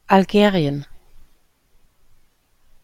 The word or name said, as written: algerien